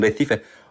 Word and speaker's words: Recife: Ich würde nach Brasilien, Recife und, das muss man auch so aussprechen, Recife und João Paulo, okay.